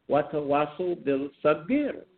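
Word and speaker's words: watawasul bil sabir (وتواصوا بالصبر): It is pronounced watawasul bil sabir. Don't try to take the burden on by yourself. Again, it is watawasul.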